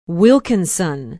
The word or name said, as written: wilkinson